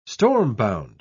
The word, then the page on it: stormbound: British English